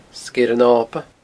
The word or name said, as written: Sgeir an Oba